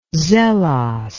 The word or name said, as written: zelos